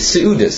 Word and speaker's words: Seudis: But with the Yiddish pronunciation, how do you say Seudot in like a Yiddish pronunciation? Seudis. Seudis. They say U is E.